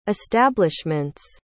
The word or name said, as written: establishment's